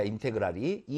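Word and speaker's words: integral: 인테그랄, 이걸 인테그랄, 이게 보통 S-sum이라는 개념인데, 그 sum에서 이 S를 갖다가 길게 늘어뜨린 거야. 기호의 탄생이, 그래서 인테그랄이라고 읽습니다. 예, 그리고 fx, 적분한다라는 뜻이야. 쌓아 올리겠다라는 뜻이야, 인테그랄이.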